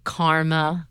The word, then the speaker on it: korma: We make korma, am I saying that right? Mm-hmm.